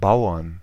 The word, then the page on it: Bauern: /ˈbaʊ̯ɐn/